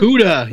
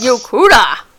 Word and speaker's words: Yokuda: Humans that hail from the lost continent of Yokudu. I always say this wrong. Yokudu? Yokudu? – Yokuda. – Yokuda. – Yokuda. – Yes. Yokuda.